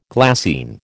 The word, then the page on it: glassine: American English